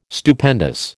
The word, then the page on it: Stupendous: American English